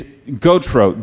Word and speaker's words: Gautreaux: It's Gautreaux, G-A-U-T-R-E-A-U-X. It's a Louisiana name, and it's spelled just like it sounds, right? Gautreaux, G-A-U-T-R-E-A-U-X.